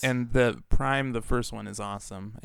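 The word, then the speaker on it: PRhyme: And then I believe it's pronounced Prime, but it's spelled like P Rhyme rapper.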